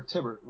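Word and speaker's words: Thibert: You think it's Thibbert or Tibbert with a silent H? – Tibbert. – God, I hate names. – I say Tibbert.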